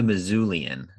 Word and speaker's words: Missoulian: He's with the Missoulian, is I believe how it's pronounced. So it sounds like you're saying Mizzou, but that's just the name of the newspaper up there in Montana.